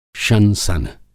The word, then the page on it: शंसन: Hindi